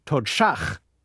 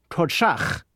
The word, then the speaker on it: Todshach: Todshach. The sound at the beginning of the second syllable, shach, is sh. It's not quite either the S or the SH sounds you're familiar with.